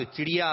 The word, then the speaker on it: چڑیا: درست تلفظ بتانا ہے. چڑیا یا چڑیا؟ دیکھا اڑتے ہوئے کیا اڑتی ہے؟ – چڑیا. – چڑیا تو نہیں اترتی. – چڑیا. – چڑیا. – چڑیا. – چڑیا. جی کیا اڑتی ہے ہوا میں؟ چڑیا. نہیں اترتی. نہیں اڑتی. کیا اڑتی ہے آسمان پہ؟ چڑیا. تو چڑیا آپ نے بالکل درست تلفظ بتایا.